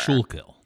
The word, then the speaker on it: Schuylkill: Schuylkill.